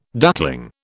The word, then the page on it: duckling: British English